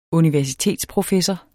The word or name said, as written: universitetsprofessor